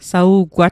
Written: sa'ugwat

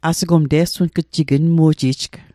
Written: te'sunatl

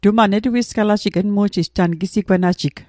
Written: netuisgalatl

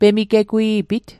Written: pemigegwi'pit

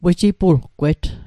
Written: wejipulgwet

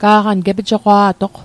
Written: gepijoqwa'toq

